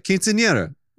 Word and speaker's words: Quinceañera: Quinceañera. – You see? – What? – Yeah, that's how you say it. Quinceañera.